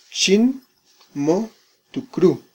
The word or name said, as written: xin mo tukru